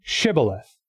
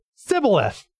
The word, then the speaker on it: Shibboleth: Say Shibboleth. And the person would say, Sibboleth, because he couldn't pronounce it right. They couldn't say Shibboleth. They pronounced it Sibboleth, and so they would kill them.